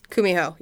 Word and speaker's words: Kumiho: Kumiho, yes.